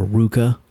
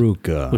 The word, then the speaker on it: RVCA: I also bought a pair of RVCA or Ruka, I've been told it's pronounced. – Ruka. – Which doesn't make any sense because there's a V.